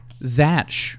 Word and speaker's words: Zach: Zach or Zach? – Zach. – Okay. Okay. Rhymes with Try snatch.